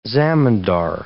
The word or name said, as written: zemindar